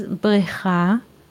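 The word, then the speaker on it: Brecha (בְּרֵכָה): Because a pool in English is Brecha. Brecha. Let's write it in Hebrew here. Brecha. Those are four letters. It means pool. Yes? But with a different nikud or pronunciation, the pool, brecha, becomes bracha, blessing.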